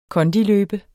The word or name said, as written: kondiløbe